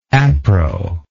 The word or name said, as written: appro